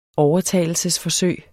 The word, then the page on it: overtalelsesforsøg: dansk